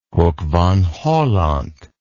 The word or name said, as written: hoek van holland